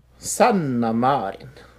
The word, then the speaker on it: Marin: The Finnish Prime Minister Sanna Marin, or Maran? Marin? – Sure. – How would you say it? – Sanna Marin.